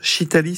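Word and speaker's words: Shitali: Voyons maintenant comment rafraîchir naturellement le corps, et vous souhaitez nous faire découvrir une technique de respiration peu connue, la respiration Chitali, si je prononce bien. – Oui, c'est tout à fait ça.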